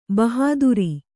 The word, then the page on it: ಬಹಾದುರೀ: bahādurī